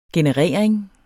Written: generering

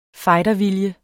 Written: fightervilje

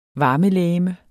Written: varmelegeme

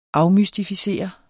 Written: afmystificere

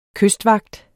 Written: KYSTVAGTEN

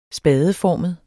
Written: spadeformet

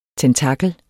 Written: tentakel

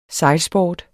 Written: sejlsport

